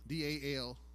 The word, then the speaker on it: dal: In the Old Testament, uh, poverty, there's a, a, a, Hebrew word called dal, D A L, but it's A is pronounced aw dal.